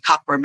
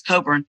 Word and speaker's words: Cockburn: He was responsible for saving Norfolk from being invaded by Admiral Cockburn, if you pronounce his name properly. Although the Americans always call it Cockburn, but it's Coburn.